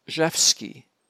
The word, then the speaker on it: Rzewski: Speaking of the mispronunciation of names, I believe the composer's name is Zhevsky, Frederick Zhevsky, R-Z-E-W-S-K-I. And his Frederick is without a K on the end.